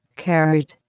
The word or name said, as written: carrot